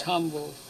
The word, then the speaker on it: Hamburg: It's Hamburg here, but in German it's Hamburg.